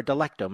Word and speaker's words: Delecto: He's a Pierre Delectico bot, or Delecto, may I say.